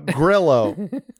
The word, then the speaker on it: Grillo: Nah, dude, it's I-O-A-N, however you pronounce that. – Bro, I think you're talking about Lean In. – Grillo. like grill with an O at the end.